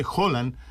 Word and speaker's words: Haaland: on je Holan, izvorno na norveškom, ali je taj evropski izgovor usvojen Erling Haaland, i svi Evropljani govore Erling Haaland, mada je on Holan bez D na kraju, eto da znaju slušalci koji čuju možda na različite izgovore. Dakle, on nije Holand, on je ili Holan ili Haaland. Sada kako ćemo da prihvatimo? – Dobre, to mislim da je Haaland nekako...